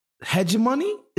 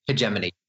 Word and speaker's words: hegemony: hegemony. Is that how you say it? – Hegemony.